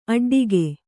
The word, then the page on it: ಅಡ್ಡಿಗೆ: aḍḍige